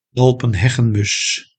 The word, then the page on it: alpenheggenmus: Nederlands